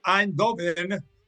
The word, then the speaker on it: Eindhoven: El Leipzig le pegó al PSV Eindhoven, bien pronunciado en Dutch, como hay que decirlo, no es sorpresa, pero esta sí.